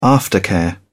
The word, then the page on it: aftercare: British English